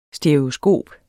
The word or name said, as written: STEREOSKOP